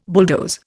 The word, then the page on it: bulldoze: Indian